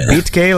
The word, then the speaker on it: Betke: I'm going to say this correctly. What, Beatke? Beatke, like that? – Yes.